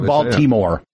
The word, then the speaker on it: Baltimore: Well, I used to do Top of the Hour at WBSB Balmer. – Balmer. – Because that's how locals say it. – Yeah, that's or Baltimore. – Baltimore.